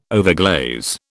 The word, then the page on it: overglaze: British English